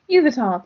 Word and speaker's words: Yveltal: It's Yveltal. – Yveltal. – Yveltal, thank you.